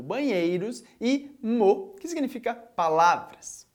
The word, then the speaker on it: mot: O termo mot, quando termina em S, está no plural, mas se ele tivesse no singular, ia terminar por T. Ainda assim, você tem que continuar dizendo só mot. Isso quer dizer que quando você vira uma palavra que termina em T, esse T não vai ser pronunciado. Por isso a gente tem mot no singular e mot no plural, a pronúncia é exatamente a mesma.